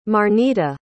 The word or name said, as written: marnita